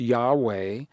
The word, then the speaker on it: Yahweh: this name, YHVH, pronounced Yahweh,